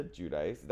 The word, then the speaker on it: Giudice: Like, Teresa Giudice. That's how you say it, right? Giudice? Right. Yeah.